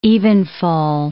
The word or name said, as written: evenfall